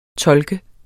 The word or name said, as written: tolk